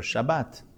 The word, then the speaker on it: Shabbat: See here, this word, you can see the two pronunciations. Do you say good Shabbos, or Shabbat Shalom? And the reason, so it's a suf, but Israelis pronounce the suf and tav the same as a T sound.